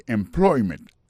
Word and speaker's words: employment: Alors, mot employment là, c'est employment. Accent tombé sous deuxième syllabe là.